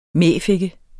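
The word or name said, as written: mæfikke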